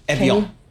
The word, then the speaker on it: Evian: Evian. Like the water.